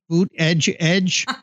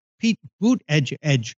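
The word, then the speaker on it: Buttigieg: His name is Pete Boot Edge Edge.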